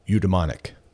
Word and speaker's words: eudaemonic: Eudaimonic. That's right. You heard me. Eudaimonic. E-U-D-A-E-M-O-N-I-C. Eudaimonic. I looked up not just the word, but its pronunciation. I'm pronouncing it correctly.